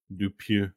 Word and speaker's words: Dupieux: Oh, is that how you say it? Dupieux? – Dupieux. – Oh, he's from France.